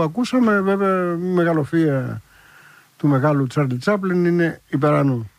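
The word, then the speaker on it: Charlie Chaplin: είναι από τους μοντέρνους καιρούς του Τσάρλι Τσάπλιν, 1936, Σαρλί Σαπλέν, που λένε και οι Γάλλοι, με αυτή την άθλια προφορά.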